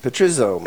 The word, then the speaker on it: Petrizzo: It's a good Irishman, Petrizzo.